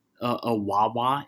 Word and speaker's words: Wawa: No, there a Wawa.